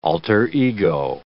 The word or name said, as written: alter ego